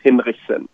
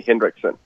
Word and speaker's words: Hinrichsen: I'm from Germany originally, so the name that you have in front of you is, pronounced Hinrichsen, but you can call me Hinrichsen.